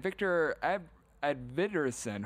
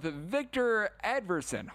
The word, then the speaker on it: Edvinsson: Mateus Elkham was able to get his first goal of the postseason, and Victor Edvinsson, hopefully I said that correctly. I completely butchered that last name, so we're going to go with Victor Edvinsson. Hopefully we got that one correctly the second time around.